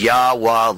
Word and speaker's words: Yahweh: And so we're changing the Psalms to the proper pronunciated name of the Most High, which is Yahweh, like the sound of not only the human heart, the breath, but also the sound of the ram's horn or trumpets.